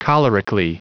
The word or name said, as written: cholerically